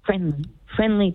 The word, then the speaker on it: FRNDLY: It wasn't spelled like friendly. It had it was missing some of the letters. So give me a second here. – Yeah. – It is. So it's FRNDLY.